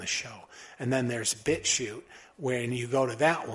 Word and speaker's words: BitChute: Some people think BitChute is spelled like S-H-O-O-T, but it's not. It's spelled bit and then shoot like a coal shoot, like BitChute, like C-H-U-T-E.